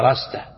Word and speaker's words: رستة: أي هي كلمة تكتب بالألف لكن العرب ينطقونها برستة، لكن تنطق هكذا رستة.